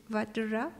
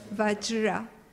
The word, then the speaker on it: Vajra: But even that, when, for example, it said, when we will read later, when Britasura was killed, is that the Vagra, so the pronunciation is not easy for me, the thunderbolt, the weapon of the Indra's weapon, the thunderbolt is Vajra. Vajra.